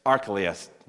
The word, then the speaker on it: Archelaus: Archelaus, well done.